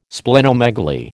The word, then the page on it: Splenomegaly: American English